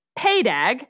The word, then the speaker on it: PADAG: So it's PADAG. And for some reason, that is pronounced PAYDAG.